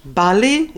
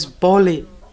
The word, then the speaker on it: Bali: Bali, it's short A, so it sounds in Bengali as Boli.